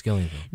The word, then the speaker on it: Gillian: Yes, Gillian.